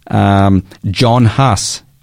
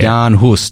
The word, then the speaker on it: Jan Hus: John Huss, or yeah. Jan Hus, to be more accurate with the pronunciation, a church priest from Bohemia and a professor at the University of Prague.